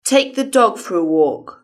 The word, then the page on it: take the dog for a walk: British English